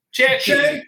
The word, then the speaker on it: Cerchi: Cerchi. – Cerchi. All right, everybody. So congratulations. – Cerchi. Is that like cercare to look for? – It's like Cerchi rounds.